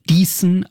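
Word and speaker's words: Dießen: Diesen am Ammersee. – How do we spell it? – D-I-E-S-S-E-N.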